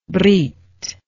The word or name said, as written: brith